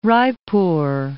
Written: Raipur

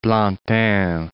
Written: Plantin

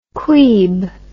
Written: queeb

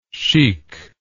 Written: chicness

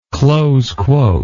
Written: close quote

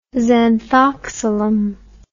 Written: xanthoxylum

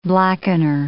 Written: blackener

